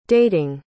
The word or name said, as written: dating